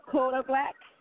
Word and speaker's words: Kodak Black: It's going to be Gucci Mane, Kodak Black.